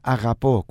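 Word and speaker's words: αγαπώ: Ojo con la palabra agapó. Cuando hablamos agapó, la palabra r se pronuncia igual que la r francesa. Por ejemplo, los franceses, como dirían la palabra programa, dirían programa. Por ejemplo, como dirían Rodrigo, Rodrigo. Por lo tanto, esta palabra también se agapó, también lo pronunciaríamos igual.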